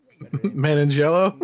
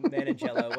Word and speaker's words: Manganiello: Magliano. Or Joe Manganiello? – Manganiello, whatever.